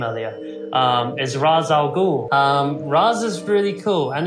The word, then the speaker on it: Ra's al Ghul: The problem is that it's an Arabic name, and the pronunciation in Arabic is Ra's al Ghul.